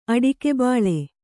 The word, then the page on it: ಅಡಕೆಬಾಳೆ: aḍakebāḷe